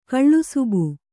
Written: ಕಳ್ಳುಸುಬು

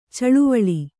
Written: ಚಿಲಿವಾಲು

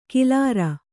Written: ಕಿಲಾರ

